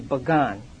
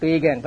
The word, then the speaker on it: Bagan: You may have heard of the city Bagan. It is not pagan, but Bagan. It is spelled P-A-G-A-N.